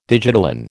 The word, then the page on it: digitalin: American English